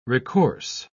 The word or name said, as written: recourse